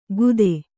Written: ಕುದೆ

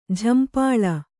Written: ಝಂಪಾಳಿ

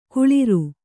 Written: ಕುಳಿರು

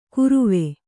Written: ಕುರುವೆ

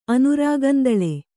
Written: ಅನುರಾಗಂದಳೆ

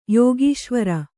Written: ಯೋಗೀಶ್ವರ

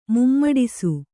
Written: ಮುಮ್ಮಡಿಸು

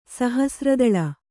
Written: ಸಹಸ್ರದಳ

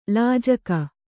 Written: ಲಜ್ಜೆಗಾ

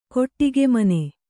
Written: ಕೊಟ್ಟಿಗೆಮನೆ

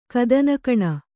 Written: ಕದನಕಣ

